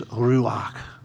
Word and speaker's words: ruach: Yes, there's a Jewish word which means, which is ruach. I hope that's the right pronunciation.